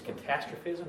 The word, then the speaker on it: catastrophism: Uniformitarianism versus, how do you say this one? Is it catastrophism or catastrophism? I think it maybe is catastrophism, is that right? – Yeah.